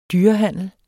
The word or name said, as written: dyrehandler